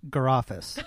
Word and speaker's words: Gare Office: And it's pronounced Garofis. – Oh, I'm so sorry. – Garofis, yeah. – Because it says office in the garage.